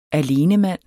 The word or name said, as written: alenemand